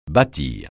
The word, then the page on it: bâtir: francais